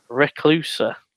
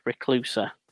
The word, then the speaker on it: Reklusa: Reclusa. That's spelled R-E-K-L-U-S-A. Reclusa.